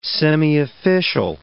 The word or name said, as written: semiofficial